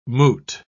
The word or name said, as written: moot